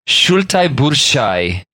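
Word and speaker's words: Šaltibarščiai: Salty barshel, which I've definitely pronounced wrong. – Yeah, it sounds like you absolutely decimated that. – Let me try this again. Shultai burshai. – That sounds better.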